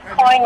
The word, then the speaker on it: Coyne: My last name is Coyne, like Toss a Coin.